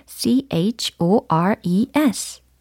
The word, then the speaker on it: chores: 어, C로 시작하죠. C, H, O, R, E, S 라는 철자입니다. 발음을 한번 해보세요. C, H, O, R, E, S. chores, chores. 네, 이렇게 발음을 하셔야 되겠어요.